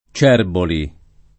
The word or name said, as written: Cerboli